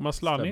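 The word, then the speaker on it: Maslany: Uh, og der får vi se Tatjana Maslani. Nei. Jo, jo Maslani. Stemmer det.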